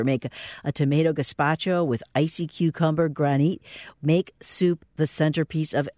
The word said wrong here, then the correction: granita: Well, it's pronounced granita, and granita.